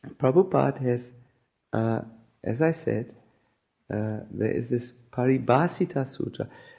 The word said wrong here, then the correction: Paribhasha Sutra: Can you please say it correctly? I may not say it correctly. Paribhasha Sutra. Paribhasha Sutra.